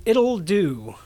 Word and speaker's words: Ittle Dew: We're doing It'll Do, which is spelled I-T-T-L-E-D-E-W.